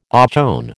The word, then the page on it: ochone: American English